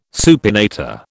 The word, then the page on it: supinator: British English